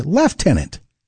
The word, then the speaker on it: Lieutenant: It's spelled C-O-L-O-N-E-L, but pronounced Colonel. And right behind it is Lieutenant. In Canada, we say Lieutenant. There's no F there.